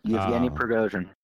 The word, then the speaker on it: Yevgeny Prigozhin: I hope I'm saying that right. – Pretty good. Yevgeny Prigozhin.